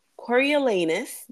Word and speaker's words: Coriolanus: We're finished with the book, and I just want to say Coriolanus, because that's how you say his name, is absolute trash.